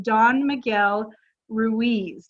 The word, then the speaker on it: Don Miguel Ruiz: And she was ranting and raving about the four agreements from Don Miguel Ruiz.